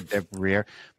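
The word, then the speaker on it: Deriviere: I love Olivier Defrier. Oh my God, I always get his name wrong. Olivier Devrier, but he's the composer behind Plague Tale: Innocence, Remember Me, and also Dying Light.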